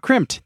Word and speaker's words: Crimpd: Crimped is spelled C-R-I-M-P-D. That's crimp with a D at the end,